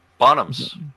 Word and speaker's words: Bonhams: Bonhams.